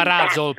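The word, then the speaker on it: Ruzzle: Si chiama Razzle, pazza. Poi comunque lasciamo perdere. Razzo, comunque lasciamo perdere. – Vabbè, però si scrive Ruzle, no? – Sì, beh, ho capito, sì, però la scrittura e la dicitura sono due cose diverse.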